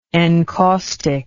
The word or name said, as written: encaustically